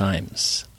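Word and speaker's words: Ijames: So the New York Times says it's James Iams, I-M-E-S. His surname is pronounced Iams. – I-M-E-S.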